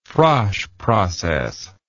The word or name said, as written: Frasch process